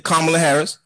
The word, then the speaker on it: Kamala Harris: Yeah, Kamala Harris, and she has a white husband.